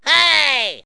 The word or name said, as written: hey